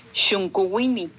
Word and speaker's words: Xuncuwini: Es una, son palabras en zapoteco y se pronuncia Xuncuwini, que quiere decir el más chico de la familia, pero es el Xunco es el más chico de la familia, pero el Wini quiere decir el chiquito o el niño.